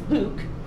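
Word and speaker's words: LIWC: Word Count, or pronounced Luke, which is also interesting.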